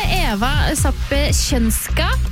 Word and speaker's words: Eva Sapkowska: En som har masse kunnskap om Polen og politikken, det deg det, Eva Sapkowska. Prøv meg på å uttale navnet ditt. – Du, ja, veldig bra.